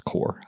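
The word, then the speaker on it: Qore: I think it's just pronounced Core. I don't know, but it's a Q-O-R-E, I believe.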